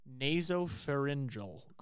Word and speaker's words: nasopharyngeal: She was eliminated the very next round with the word nasopharyngeal.